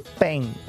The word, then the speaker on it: Paine: ¿Paine o Paine? – Paine. Pongámosle Tim. – Se escribe Paine.